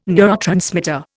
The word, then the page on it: neurotransmitter: Indian